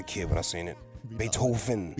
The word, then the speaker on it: Beethoven: Have we been pronouncing his name wrong? – No, Beats the hell out of me. – It's definitely Beethoven, right? – Beethoven.